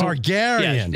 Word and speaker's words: Targaryen: Is that how you say it? – It's a new dinosaur, actually. It's Tyrion Lannister. – Yes. Yeah. No, it's not Lannister. – Oh, Daenerys Targaryen. – Yes, it's Targaryen.